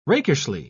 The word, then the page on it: rakishly: British English